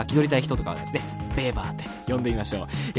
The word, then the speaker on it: Weber: 名字にあたるこのファミリーネーム、あのスペルがですね、あの WEBER、あのウェーバーなんですけども、あのプロイセンおよびドイツの出身なので、ドイツ語発音するとベーバーと濁ります。